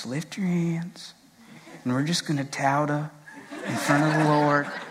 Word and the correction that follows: toda: And then I like actually looked it up phonetically and it's toda. So all these years of me teaching this and now I know how to say it. So toda, I almost said tauda again.